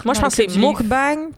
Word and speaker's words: mukbang: J'ai lu Mokbang. Mukbang.